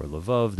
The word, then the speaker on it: Lvov: You recently came back from an extraordinary trip to Eastern Europe, and we started talking about Lviv, or Lvov, depending on how you pronounce it,